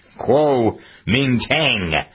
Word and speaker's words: Kuomintang: Kuomintang.